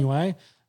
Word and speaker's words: Inoue: was it Intani? How do you say the – think he's Japanese. Scroll up, Jimmy. Scroll. This guy's name. – Oh, the guy that Kostyuzu fought? Yeah. Inoue, maybe? – Inoue.